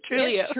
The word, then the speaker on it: Trulioo: By the way, am I mispronouncing Trulio? Correct me here. – It's Trulio. Trulio.